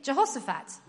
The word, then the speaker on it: Jehoshaphat: Jehoshaphat.